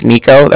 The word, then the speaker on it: Niko: Okay, it's like I said, my name is Nico. That's spelled N-I-K-O. And the Bank of Cardiff is spelled C-A-R-D-I-F-F, as in Frank.